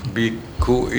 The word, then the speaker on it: biksu: Biku itu panggilan resmi atau sebutan dalam misalnya surat menyurat, tapi kalau dalam sehari-hari kita manggilnya Bante. – Oh, biksu itu bacanya biku Pak. Biku.